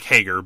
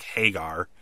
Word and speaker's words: Hager: Then he introduced Jake Hager, who Jericho pronounces his name like he's Sammy Hagar from like Van Halen or whatever. His name is Jake Hager, but Jericho kept calling him Jake Hagar.